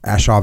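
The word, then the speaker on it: A Shau: Ashaw Valley. Am I saying that right? – Ashaw Valley.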